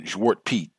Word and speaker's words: Zwart Piet: And then Zwart Piet, that's how they say it over there, Zwart Piet, Black Pete, that has been going on for a long, long time as well.